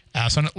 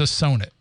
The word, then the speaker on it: Assonet: Like they're like, oh, we, we were in the Freetown Forest and we were at the ass on it ledge. Like, no, it's Assonet. Learn to pronounce things the right way, especially when you know Massachusetts is like that.